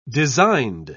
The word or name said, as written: designed